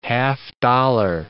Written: half-dollar

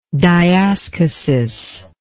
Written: diaschisis

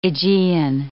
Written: Aegean

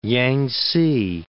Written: Yangtze

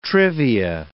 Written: trivia

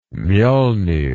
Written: mjolnir

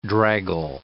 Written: draggle

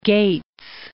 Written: Gates

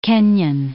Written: Kenyon

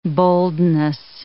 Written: boldness

